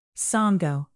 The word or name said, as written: sango